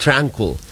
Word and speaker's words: Tranquil: Pero ang bigkas po niyan sa English, Tranquil.